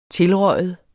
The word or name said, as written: tilrøget